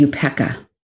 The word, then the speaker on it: upekkha: And the Pali word for equanimity is upekkha.